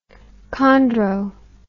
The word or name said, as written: chondro